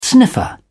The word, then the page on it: sniffer: British English